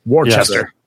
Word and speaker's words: Worcester: But yeah, the it's funny because we're from Massachusetts where we don't pronounce anything as it's spelled. So I guess we... – But we'll still let people know when they're pronouncing Worcester incorrectly. Worcester.